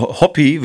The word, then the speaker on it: Hoppe: so eine Hobby, wird er ja ausgesprochen. – Der Hoppe.